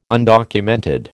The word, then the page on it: undocumented: American English